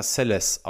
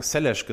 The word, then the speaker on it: Seles: Monika Seles spricht sich selber Monika Seles aus, wenn man sie in Interviews hört ich werde das nicht hinkriegen. Ich habe mein Leben lang Selesh gesagt und ich glaube nicht, dass mir das gelingen wird, in diesem Podcast äh, jetzt einmal umzuswitchen.